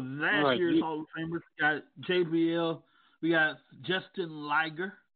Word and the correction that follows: Jushin Liger: I believe. That's how you pronounce it. – Jushin Thunder Liger. – Jushin Liger.